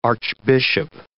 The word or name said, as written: archbishop